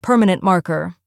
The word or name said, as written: permanent marker